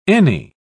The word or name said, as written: any